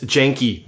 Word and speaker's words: Janke: And Dennis Janky. That one we're supposed to pronounce the E.